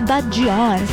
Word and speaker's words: Bad Gyal: Oye, Matcher, prepárate porque Bad Gyal, o como dices, ¿cómo se pronuncia bien? Perfectamente, Bad Gyal. Bajal.